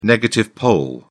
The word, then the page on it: negative pole: British English